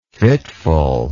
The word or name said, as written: fitfulness